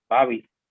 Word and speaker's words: Bhavi: Se escribe B-H-A-V-I, Babi, y Cinema.